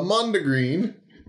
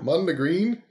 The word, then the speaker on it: Mondegreen: According to Merriam-Webster Dictionary, a Mondegreen, is that how you say it? – Yeah. – Mondegreen? – I don't know.